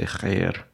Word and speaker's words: khair: But at least when you say khair, it's good to pronounce it that way.